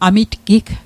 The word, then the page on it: amitgig: Mi'gmaq